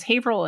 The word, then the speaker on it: Haverhill: They literally, it's Haverhill and you don't pronounce the E.